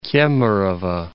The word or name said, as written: Kemerovo